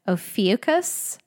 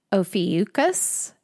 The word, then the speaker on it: Ophiuchus: And if any two astrologers cannot agree on the story of this sign and its place and its relevance within astrology, neither can we agree on the pronunciation. It's sometimes described as Ophiuchus, Ophiuchus, Ophiuchus, Ophiuchus, Ophiuchus. So it's spelled O-P-H-I-U-C-H-U-S. Given my mood, my pronunciation changes too. So today, maybe it's going to be Ophiuchus.